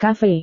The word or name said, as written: kafe